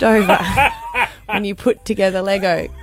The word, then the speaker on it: Lego: Lego? – You said it right. – Not Lego for those from Adelaide, but Lego. The age of it was 5 to 7 year old.